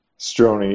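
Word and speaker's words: Strojny: Strozny. You just don't pronounce the J.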